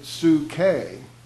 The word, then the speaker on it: psyche: In fact, in the New Testament, the soul is, you could say psyche, or to really phonetically say it right, Sue Kay.